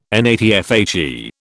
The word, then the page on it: NATFHE: British English